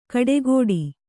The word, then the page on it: ಕಡೆಕೊಡಿ: kaḍekoḍi